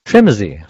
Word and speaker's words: Trimzi: Trimzi.